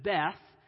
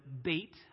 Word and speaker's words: Beth: Now, Aleph over verse 1, over verse 9, it says Beth. Technically, we should pronounce it Beit. It just means house.